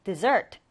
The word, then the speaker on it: dessert: 이 디저트 같은 경우에는 이렇게 철자를 S를 두 번 쓰고요. 그리고 강세도 다릅니다. 강세가 디딤절에 와서요. dessert, dessert 이렇게 발음합니다.